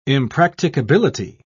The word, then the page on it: impracticability: British English